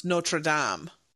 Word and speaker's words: Notre Dame: But really it should be pronounced Notre Dame. but we say Notre Dame.